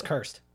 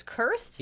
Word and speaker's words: Krist: It's cursed. – Oh, wait, it's cursed? – Yeah, it's cursed. K-R-I-S-T.